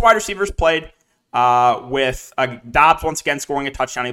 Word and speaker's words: Doubs: So I just found out, apparently on Twitter, you pronounce his name Dobbs. It's not Romeo Dubes. It's Romeo Dobbs, but it's spelled D-O-U-B-S.